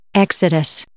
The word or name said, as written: exodus